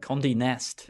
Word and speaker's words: Condé Nast: The rights to Slater's life story last year were bought by Condé Nast Entertainment. – Condé Nast. – Is that how you say it? – I believe so.